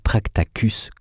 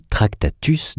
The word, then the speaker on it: Tractatus: Attention à ne pas prononcer Tractacus comme un profane. L'idée générale du Tractatus, donc, est fascinante.